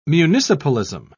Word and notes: municipalism: British English

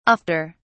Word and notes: ofter: British English

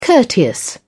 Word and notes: courteous: British English

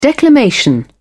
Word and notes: declamation: British English

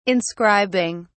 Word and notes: inscribing: British English

